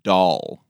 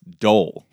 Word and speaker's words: Dahl: I would spell it D-A-H-L. Doll. – Yes. – Dolphins. – Doll. – I... – No, you're saying like bananas, Dole. Like Dole bananas. – No, like D-O-L-E, that's Dole.